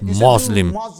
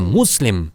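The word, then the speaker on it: Muslim: Еще говорят «Мазлим». Все время произносят «Мазлим, Мазлим». По сути дела, никого еще не оскорбляли. Я муслим, муслим. Я муслимин с буквой син, а не с буквой z или же ва.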